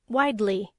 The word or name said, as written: widely